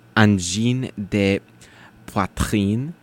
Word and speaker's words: Angine de Poitrine: Because we have a song by a band called Angine de Poitrine. – Oui. – Did I pronounce that? – Almost. – Yay. – You have to get your de, more like de, not de, de. Angine de poitrine.